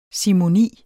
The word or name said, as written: simoni